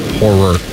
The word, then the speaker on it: horror: Horror. – Two syllables. Horror.